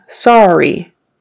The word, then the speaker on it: sorry: Okay, the word sorry. It's spelled with an O-R-R-Y, but it kind of sounds like an A-R. Sorry, R-R, sorry. Sorry, I couldn't make it. The word senior, like S-E-N-I-O-R, senior, you're older, you're a senior, or maybe you are in your last year of high school, you're a senior.